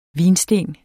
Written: vinsten

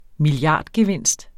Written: milliardgevinst

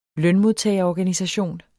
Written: lønmodtagerorganisation

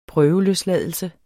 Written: prøveløsladelse

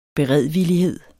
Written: beredvillig

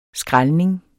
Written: skrælning

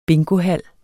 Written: bingohal

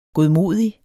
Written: godmodig

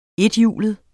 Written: ETHJULET

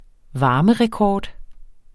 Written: varmerekord

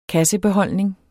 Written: kassebeholdning

